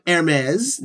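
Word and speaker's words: Hermès: E, clothing from Hermes.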